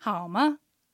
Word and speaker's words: 好吗: アクセントはハオが賛成、マが、軽い音、軽声です。まというようにちょっと音を高く発音すると、うん、疑問らしく、はい、聞こえます。では練習してみましょう。 – 好吗？ – 好吗？